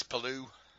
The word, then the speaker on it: Palou: and then Alex Palou, I think it is. – Alex Palou.